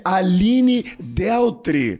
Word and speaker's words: Aline Dealtry: Vamos ver se eu vou pronunciar certo. Eu estou falando de Aline Deltri. – Perfeito.